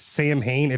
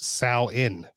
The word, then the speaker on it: Samhain: And I was just going to say that I saw somewhere that it's, even though it looks like it's Samhain, it's actually pronounced Sal-in.